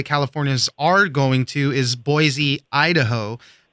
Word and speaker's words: Boise: It's Boise. B-O-Y-S-E-E is how you pronounce it.